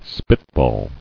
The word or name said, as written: Spitball